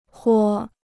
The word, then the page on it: 豁: huá